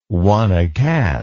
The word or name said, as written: one-a-cat